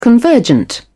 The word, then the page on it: convergent: British English